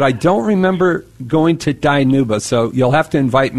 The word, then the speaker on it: Dinuba: Where is Danuba? – It's actually pronounced Dinuba.